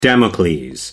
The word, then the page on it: damocles: American English